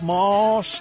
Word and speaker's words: Maus: All right, we are going to do it right after I tell you about Moss Nissan. Moss is spelled M-A-U-S, Nissan.